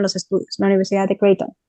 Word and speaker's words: Creighton: Creighton se escribe C-R-E-I-G-H-T. – T-O-N, Creighton.